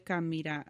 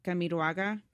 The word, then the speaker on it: Camiroaga: Oh, Selena Quintanilla, a Chilean actor, Felipe Camira. I can't say his name. Camiroaga.